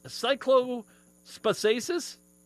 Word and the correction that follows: Cyclosporiasis: Cyclosporiasis. – Cyclosporiasis.